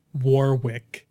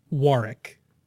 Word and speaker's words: Warwick: You guys pronounce it Warwick when it is correctly pronounced Warwick. Warwick is a place in England and also a county, Warwickshire.